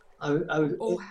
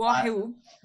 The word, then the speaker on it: Oahu: In 1974, you were living in, how do you pronounce that island's name? – Oahu is the capital. – Oahu.